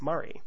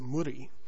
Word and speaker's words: Murri: Here in Queensland, the term Murray is used fairly often, and although us white fellas tend to pronounce it Murray, I have heard Indigenous people pronounce it Murri, rolling their R's, and since it's their word, I guess that's the proper way to do it.